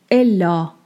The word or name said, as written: Ellā